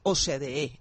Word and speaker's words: OCDE: Es el ingreso de Colombia a la OCDE, OCDE, finalmente, ¿cómo lo pronunciamos mejor? – ¿Así? OCDE. – OCDE, exactamente, que es una noticia muy importante.